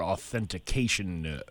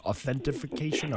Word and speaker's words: authentication: You know, interesting thing you said about the two-factor authentication. How do you say that word? Authentification? – Authentication. – Authentication.